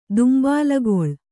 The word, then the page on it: ದುಂಬಾಲಗೊಳ್: Kannada